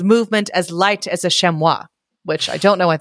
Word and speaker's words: chamois: And it's pronounced chamois.